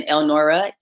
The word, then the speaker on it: Elnora: So it's uh, Latoya, L A T O Y A, and then Elnora, E L N O R A dot com.